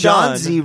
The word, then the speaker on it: Johnsy: Well, no, John Z rhymes with Fonzie, dude.